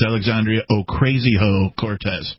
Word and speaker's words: Alexandria Ocasio-Cortez: I have to correct you. It's Alexandria Ocasio-Cortez. That's how the pronunciation is.